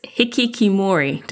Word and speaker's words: hikikomori: In your book, you have a section, Randy, on, and I'm going to try and pronounce this, hikikomori. Did I get that right? – You did, yes.